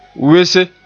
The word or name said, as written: wese